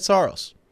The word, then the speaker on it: Saros: Look, I went last year off of the official pronunciation guide that the Predators sent out. And in that, it said Saros.